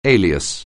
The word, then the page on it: alias: English